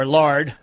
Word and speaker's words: Lard: It's my contention that Cameron Laird, or Lard, how do you pronounce it, just so I say it correctly? – Lard.